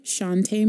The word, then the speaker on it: Chante: Um, so, I'm on Instagram at by Chante Marie.